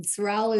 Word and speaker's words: Sorrelle: Sorrell is spelled S-O-R-R-E-L-L-E.